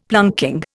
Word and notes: planking: Indian

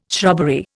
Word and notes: shrubbery: Indian

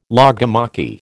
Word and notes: logomachy: American English

